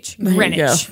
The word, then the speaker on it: Greenwich: In your defense, there's a fucking W in there. – Yeah. If they didn't want me to mispronounce it, then they should have spelled it. Okay, what is it? Greenwich? G-R-I-N-I-C-H. Greenwich. There you go.